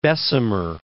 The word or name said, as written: Bessemer